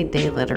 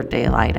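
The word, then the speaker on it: Daylyt: It's spelled, well, you can say Daylit or Daylight actually, which I don't even know which one I prefer. It's D-A-Y-L-Y-T.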